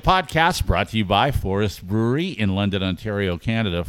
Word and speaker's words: 4EST: It's Forest Brewery. Like, that's what it is. But it's spelled with a 4 and an E-S-T.